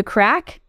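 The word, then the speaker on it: craic: So I would say, what's the crack? Did I say it right? – Yeah.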